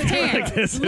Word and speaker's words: Catan: Yes, Catan.